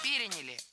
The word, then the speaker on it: переняли: Мы успешно эту традицию переняли, и Новый год превратился в главное зимнее светское событие. – Переняли. И это тоже ошибка. Правильный вариант – переняли. Ударение ставим на третий слог.